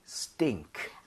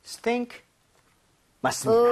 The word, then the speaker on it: stink: Stink. – 아! Sting, sting, stink. – Okay. Stink, 맞습니다.